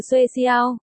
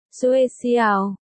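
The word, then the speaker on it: xuê xiao (学校): Hồi nãy khi Vũ Văn Tuần nói với Thanh Ti, ảnh dung từ xuê xiao, học giáo, phát âm, xuê xiao,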